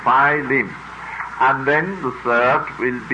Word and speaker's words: Philemon: I knew you would have two or three pronunciations of it. But it's Philemon. Marjorie, you looked it up. Philemon, that's the third, you see.